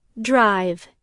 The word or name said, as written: drive